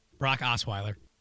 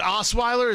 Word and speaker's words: Osweiler: Brock Oster something. – Brock Osweiler. – Was it Osweiler? Is that how you pronounce it? – Yeah.